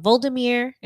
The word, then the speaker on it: Volodymyr: Ukrainian President, oh my God, Volodymyr. Did I say that right? – Oh, yeah, that sounds great.